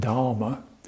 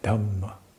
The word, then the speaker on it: Dhamma: So it's not Dharma, but Dhamma.